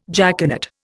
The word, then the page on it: jaconet: Indian